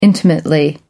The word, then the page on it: intimately: British English